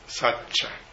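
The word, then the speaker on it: sacca: entonces se convierte en sacha en pali. La C en pali se pronuncia como cha, siempre.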